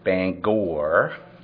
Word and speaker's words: Bangor: It's pronounced Bangor.